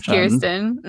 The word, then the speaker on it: Kirsten: And I'd like to redeem myself here because I did mispronounce her name on a couple episodes ago. I do know her name is Kirsten.